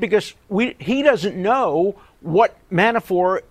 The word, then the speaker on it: Manafort: Alan Dershowitz on Meet the Press yesterday, frankifying the name Manafort, calling him Manafort, as for some reason he always does, with a silent T.